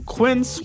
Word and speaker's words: Quince: Today Explains support today comes from Quince, which rhymes with since but is spelt with a Q-U.